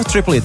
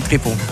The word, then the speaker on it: Triple: En kent u natuurlijk allemaal van de Triple It. Zo zeg ik het goed, hoor. Triple It, toch? Nee, triple gewoon. Triple? Ja, het heette vroeger Triple IT, maar we hebben het veranderd in triple.